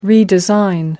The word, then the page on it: redesign: British English